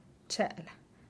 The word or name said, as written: chelaa